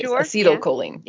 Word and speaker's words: acetylcholine: So that residue that was on Teal'c's uniform is similar to a neural transmitter called cortical acetylcholine. I think that's how you pronounce it. Acetylcholine, acetylcholine. That's it is. – Sure, acetylcholine.